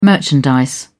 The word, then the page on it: merchandise: British English